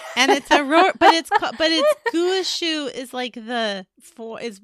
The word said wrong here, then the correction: gua sha: I am saying it right, gua shu. Yeah. I mean, like you can go get a massage, but you can do it to yourself. But that is no gua sha, gua sha.